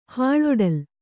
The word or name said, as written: ಹಾಳೊಡಲ್